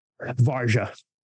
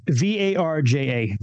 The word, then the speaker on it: Varja: Varja.